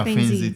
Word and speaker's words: Fainzy: So for your Fainzy, am I pronouncing it correctly? – Yeah, Fainzy Technology.